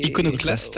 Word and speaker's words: iconoclaste: presque iconoclable. – C'est iconoclaste. Iconoclaste, ouais, ouais, je vais y arriver.